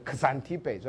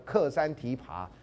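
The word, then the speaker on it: Xanthippe: 所以苏格拉底的太太呢是著名的这种非常凶悍的女人啊，叫 Xantippe 啊，有的叫要念成 Xantippe，说克山提爬。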